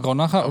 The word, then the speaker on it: Ronacher: Spricht man so aus? Ronacher, glaube ich. Ronacher, okay, passt.